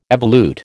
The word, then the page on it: evolute: American English